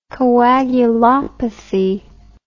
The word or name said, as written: coagulopathy